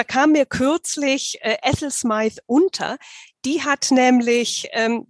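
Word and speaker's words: Smyth: Smythe ist richtig ausgesprochen, ne? – Smythe ist genau. richtig ausgesprochen. Also wie Smith nur mit Y.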